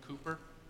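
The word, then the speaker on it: Cowper: named William Cooper. Cooper spelled with a C-O-W-P-E-R.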